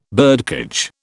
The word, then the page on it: birdcage: British English